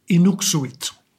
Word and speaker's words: Inuksuit: And first of all, I just want to make sure that I do pronounce this piece right because I heard you say it once, and you said Inuk Suit, and others say it would be Inuk Shoot. So what's what's the truth here? – the, the truth is, none of us speak Inuit, so we probably can't pronounce it. Um, okay. But I've actually always said Inuksuvit, which is as close to what I hear uh, my friends and neighbors up north uh, say.